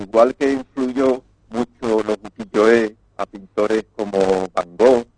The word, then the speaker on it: ukiyo-e: Y todo ello, pues, inspirado en la técnica del ukiyo-e.